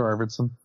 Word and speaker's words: Arvidsson: Arvidsson? Is that how you say it? – Yeah, Victor Arvidsson.